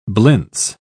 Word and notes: blintz: British English

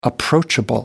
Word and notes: approachable: American English

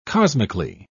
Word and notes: cosmically: British English

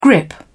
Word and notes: grip: British English

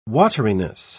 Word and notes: wateriness: British English

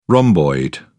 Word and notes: rhomboid: British English

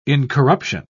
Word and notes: incorruption: British English

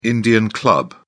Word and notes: indian club: British English

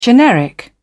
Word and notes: generic: British English

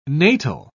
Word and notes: natal: British English

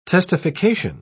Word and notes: testification: British English

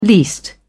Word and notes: least: British English

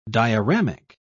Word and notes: dioramic: British English

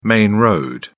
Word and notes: main road: British English

